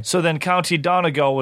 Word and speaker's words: Donegal: Donegal.